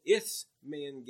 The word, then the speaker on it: Isthmian: Now listen, history records the Isthmian Games. It's spelled I-S-T-H-M-I-A-N.